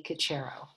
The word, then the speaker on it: Cocharo: Cocharo.